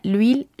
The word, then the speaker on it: l'huile: L apostrophe H-U-I-L-E espace C-H-E-V-E-U-X. L'huile cheveux. La palabra aceite es una palabra femenina en francés existen dos tipos de H, recordemos H muda y H aspirada, H muda de origen latino, H aspirada, palabras extranjeras, ninguna de las dos se pronuncia.